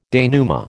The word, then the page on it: denouement: American English